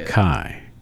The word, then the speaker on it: Takei: Well, okay, now, how would you pronounce this next birthday? George? – I think it's George Takei. – Takei. – I think that's what Takei. T-A-K-E-I.